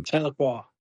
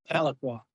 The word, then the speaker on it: Tahlequah: Tahlequah. – Oh, so you know the city. Tahlequah? – Tahlequah.